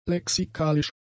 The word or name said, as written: lexikalisch